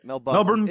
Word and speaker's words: Melbourne: Melbourne. The locals pronounce it Melbourne.